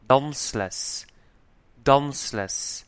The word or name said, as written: de dansles